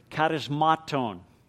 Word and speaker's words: charismaton: The Greek word for gifts there is a Greek word charismaton. And charismaton, you might say, that sounds like the English word charismatic. Well, it should, because the English word charismatic is just the English pronunciation of this Greek word charismaton.